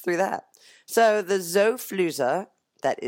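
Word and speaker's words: Xofluza: Yeah, but it's spelled with an X, so it's Zofluza. XO, then Fluza, just how Fluza sounds with a U, not two O's.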